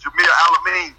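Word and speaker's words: Jamil Al-Amin: How you pronounce his name? Bilal Amin? – That's Jameel Alameen.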